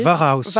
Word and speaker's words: Warhaus: Varhaus, est-ce que je le prononce bien? W-A-R-H-A-U-S. Vous voyez? – Varhaus. – Varhaus. – Var qui veut dire guerre en allemand.